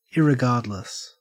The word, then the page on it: irregardless: Australian English